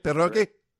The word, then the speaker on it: pierogi: You know, the standbys, your pierogi. Isn't that how you say it? Pierogi? – Pierogi, right.